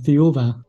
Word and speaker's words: Thiaoooba: Yes, the French pronunciation is Thiaoooba. – Okay. – And the English pronunciation is Thiaoooba, whichever you like. – Thiaoooba. Oh, so it's more of like a V sound, not a B sound? – Yes.